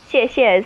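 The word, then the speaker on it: xie xie (谢谢): Like, if I'm pronouncing it correctly, thank you would be xie xie. – Xie xie is thank you. That's right, yes.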